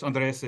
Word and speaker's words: Andres: Yeah, it's Spanish. Andres, if you don't speak Spanish.